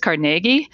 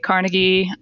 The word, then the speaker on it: Carnegie: So the Scottish pronunciation is Carnegie, but I find that folks more often say Carnegie. I use it interchangeably. You'll hear me say it both ways.